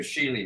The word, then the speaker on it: Sheley: Sheley, right.